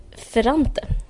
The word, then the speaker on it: Ferrante: Elena Ferrente. – Exakt. Ja. Uh, jag ska, ett ögonblick ska bara ta fram hennes namn så jag uttalar det rätt. Uh, eh, Ferrante.